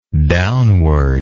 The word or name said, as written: downwardness